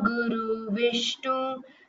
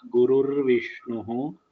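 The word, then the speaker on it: Gurur Vishnuhu (गुरुर्विष्णुः): Tasmai Shri See, you should practice the pronunciation properly. Gurur Brahma, Brahma, Gurur Vishnuhu, Gurur Devo Maheshwaraha. So there are subtle improvements which can be made in your pronunciation.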